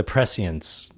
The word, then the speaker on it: prescience: And I don't know if that's even how you pronounce that word. Prescience? – No, it is.